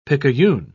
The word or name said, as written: picayune